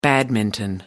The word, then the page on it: badminton: American English